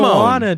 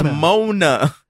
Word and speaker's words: Moana: Yeah, but Mona, M-O-A-N-A. – So you're going to say Timine. So Simine? – No, Timon. Like Simone. – Moana, dude. It's Moana. – It's Mona.